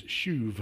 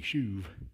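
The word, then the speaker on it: shuv (שׁוּב): Now, according to the Strong's Concordance, the Hebrew word for restoreth is shuv. It's actually spelled S-H-U-W-B, but it's pronounced S-H-O-O-V-E, shuv.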